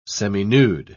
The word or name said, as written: seminude